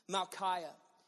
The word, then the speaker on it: Malchiah: The name I want you to focus in on here is Malchiah. It doesn't sound like it's pronounced that way, but that's how it's pronounced. Malchiah.